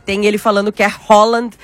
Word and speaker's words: Haaland: Ele mesmo disse, né, que a pronúncia é Haaland.